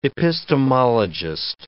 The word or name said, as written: epistemologist